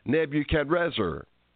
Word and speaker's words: Nebuchadrezzar: But through all of my years of reading the Bible, I never caught that till I heard Alexander Scorby pronounce it Nebuchadrezzar instead of Nebuchadnezzar. There's an R in it.